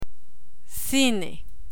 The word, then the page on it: cine: Spanish